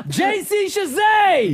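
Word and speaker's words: JC Chasez: CJ Chavez. – There you go. – No. – Wrong. JC Shazay.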